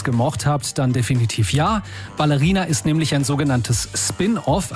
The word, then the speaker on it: Ballerina: Ballerina mit Betonung auf Baller. Kinoexperte aus dem Radio Regenbogen-Team.